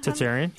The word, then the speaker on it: Tartarian: Tartarian, yeah.